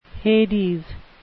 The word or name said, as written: Hades